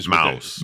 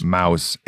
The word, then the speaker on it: Maus: M-A-U-S. – But it's one of those Mouse. – Mouse.